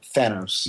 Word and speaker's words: Thanos: And then, um, uh, Thanos and Thanos has always been a debate, but mm-hmm. I think that it's um, Thanos. – Yes, actual pronunciation.